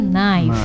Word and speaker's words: knife: Nah, pisau ini kan tulisannya pakai K ya? Ah, aku sering banget orang bacanya tuh knife. Tapi K-nya tuh silent letter. Jadi knife. – Bacanya tuh knife ya.